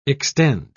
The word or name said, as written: extend